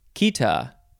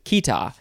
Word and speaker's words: chaeta: Next is cheeta, C-H-A-E-T-A. These first few words in this episode are hard to pronounce for me because they are not pronounced the way that they are spelled.